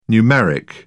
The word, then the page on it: numeric: British English